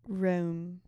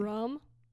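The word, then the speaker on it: rheum: Rome. – Rome? – Rem. – Rem? – It's R-H-E-U-M. Exactly. – Rum? – Yeah, exactly.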